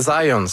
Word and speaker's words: Zajonc: Non, c'est Zajonc. – Ouais? OK. – J'ai-tu pas dit Zajonc? – Ben, vous avez dit plus Zajonc, mais. – Ah, ben, j'ai prononcé un petit peu à l'anglais. J'ai prononcé mon polonais à l'anglais.